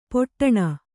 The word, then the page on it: ಪೊಟ್ಟಣ: poṭṭaṇa